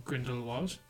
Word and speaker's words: Grindelwald: What are your thoughts on the crimes of Grindelwald? Nailed it again, Garrett, that's how you say it. – Shut up. – You still think there should be no L, imagine? – I don't think there should be.